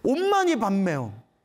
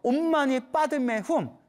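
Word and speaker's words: 옴마니 빠드메 훔 (ॐ मणि पद्मे हूँ): 옴마니 밤매움. 옴마니 밤매움이 옴마니 밤매움. 산스크리트어 발음으로는 옴마니 빠드메 훔이래요. 옴마니 빠드메 훔.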